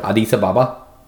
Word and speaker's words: Addis Ababa: I like to say it like all one word. Addis Ababa. – That's probably more accurate to, I would say, how the local people say it.